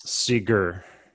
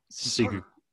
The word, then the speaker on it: Sigur: Like, how are we saying the G? I've often been confused about this. – Sigur. – Sigur. We're going hard G. Okay. – So what's the other options? – Oh, it's like a soft G.